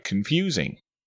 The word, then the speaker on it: confusing: Confusing starts with a schwa sound, then a long U sound, and a short I sound.